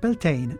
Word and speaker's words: Beltane: Beltane, in pronuncia anglicizzata, o Bioltene in irlandese moderno, che significa maggio, è la festa del fuoco luminoso, come ci ricorda l'etimologia del nome.